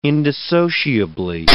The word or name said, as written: indissociably